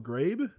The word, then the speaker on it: Ghraib: I don't even know how to pronounce Ghraib. – It. Abu Ghraib? – Yeah.